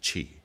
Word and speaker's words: Qi: Sometimes an older spelling, depends on the convention, the translator, there we go with language again, sometimes translated as C-H-I, Qi. You might be tempted to say Chai, but it's pronounced Qi.